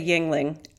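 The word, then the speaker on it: Yuengling: So, yeah, Americans would say Yingling, and it absolutely wouldn't be pronounced like that in proper German, but that's what we do. That's what Americans do. It's like, nah, it's Yingling.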